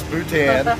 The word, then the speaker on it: Bhutan: I actually had to ask Wendy, how do I actually pronounce Bhutan? Which is a lovely, correct me if I'm wrong, a Buddhist country that's nestled somewhere between India and China. – Very well done.